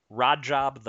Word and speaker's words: Rajab: He's facing Rajab the Python Butaev of Russia. For pronunciation purposes, I just keep telling myself it's Rajab rhymes with odd job for you Goldfinger super fans out there.